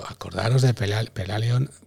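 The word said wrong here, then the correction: Pellaeon: Ah, perdón, no lo pronunciado bien. Eh, Peleón. peleón. Como el vino.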